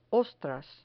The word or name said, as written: ostras